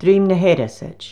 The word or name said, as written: DRUIM NA H-ERASAID